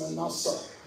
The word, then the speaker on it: Manasseh: So when we look at the name Manasseh, in the Hebrew it's pronounced Manasseh, but I probably will never say that again because I'm in the Hebrew Manasseh.